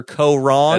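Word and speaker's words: Koh Rong: The preview for season 32 of Survivor, Ko Rong. – That's right. – Am I saying that right? – Yeah, you're saying it right.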